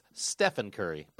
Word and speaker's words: Stephen: He's the MVP of the league, so you should say his name correctly. It's Stephon Curry.